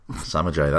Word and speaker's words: Samaje: Uh, on the other side of the ball for the uh, uh, uh for the Washington Redskins, the, uh, is it Samaji? Is that how you pronounce his name? Samaje Perine. The running back. – had a, yes. He had a good, good one. – He did. Um, yes, Samaje Perine. – Samajay, that's it.